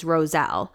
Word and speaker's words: Rowsell: My maiden name is Roselle, but it's spelled like R-O-W-S-E-L-L.